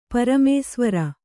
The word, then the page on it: ಪರಮೇಸ್ವರ: paramēsvara